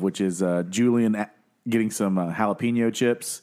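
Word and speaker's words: jalapeño: Ricky, the J is silent. It's jalapeno. – It's like an H.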